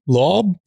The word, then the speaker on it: Loab: Because I was like, that doesn't seem right, but that Loeb.